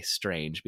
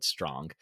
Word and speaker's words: Strange: I always want to say Strange because it just adds such a like ominous flair, but I know it is supposed to be pronounced Lord Strong. It's spelled like Strange, but every time I see it mentioned, like, historians feel the need to tell the reader immediately, pronounce this Strong.